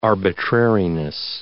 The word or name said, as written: arbitrariness